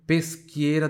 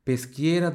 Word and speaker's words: Peschiera: Depois tem Peschiera del Garda, que é a cidade. Aqui tem uma pronúncia interessante, ó. O CHI tem som de qui. Chega aí, tem som de qui. Repita em voz alta. Peschiera del Garda.